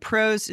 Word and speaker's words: Prose: And pros is spelled P-R-O-S-E.